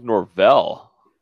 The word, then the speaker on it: Norvell: Mike Norville, Mike how do you say that? – Mike Mike Norvell.